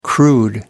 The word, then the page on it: crude: American English